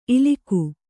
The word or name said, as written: ಇಲಿಕೆ